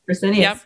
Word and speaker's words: Fresenius: Did I say that right? – No. Yes, Fresenius. Yep.